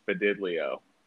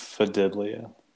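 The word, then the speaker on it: Fidelio: It's spelled like it sounds. – Yeah. – Like it sounds. – Fidilio. – Fidilio.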